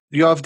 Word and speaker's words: Yoav: Yoav, did I say your name correctly this entire time at all? – Yeah.